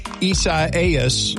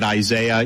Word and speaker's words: Isaias: Apparently, I'm not the only one who's had trouble pronouncing the name of the hurricane, Isaias. Laura Mark told me it was like E-I-E-I-O.